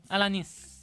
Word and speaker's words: Alanis: Come l'hai chiamata? – Alan Morissette. – Alanis.